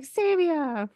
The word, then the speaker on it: Sabia: So every time I'd see her, like, Sabia rhymes with labia.